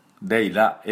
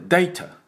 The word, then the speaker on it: data: ンフィデンシャルデータということですので、データではないですね、データ、このデータなんです、データね、データと日本人伸ばしてしまいますが、実は違います。データていう音になりますので、これも要注意です。ドゥドゥドゥドゥドゥドゥドゥドゥ、それから T のトゥトゥトゥトゥトゥ、はい、アメリカ人だとこの T が弱りますから、デイラデイラ L みたいになりますね。イギリス人だとデイタデイタとちゃんと T を発音します。